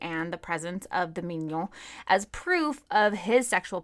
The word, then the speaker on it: mignon: Yes, but it's spelled differently, and it's pronounced mignon.